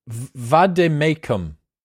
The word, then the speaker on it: vade mecum: What is a vade mecum? – A vade mecum is a Latin. – Did I pronounce it correctly? – Yes, you did.